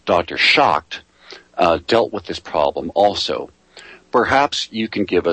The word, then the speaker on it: Problem: And the problem of living was really a problem for the German people at the time. By Lebensproblem, problem, in other words, that's just how it's pronounced in German, problem.